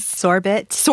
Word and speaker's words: sorbet: In fact, that story begins with me horrified by the way he pronounces sorbet. – Sorbet. – He says sorbet or something. – And then I found out later in fact-checking that sorbet is a regionalism and a perfectly valid way of pronouncing it.